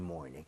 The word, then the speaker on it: morning: Every morning. 이왕이면 morning 할 때도 morning보다는 R 좀 땡겨서 more 할때 R을 혀를 많이 당겨서 발음을 해보도록 하겠습니다.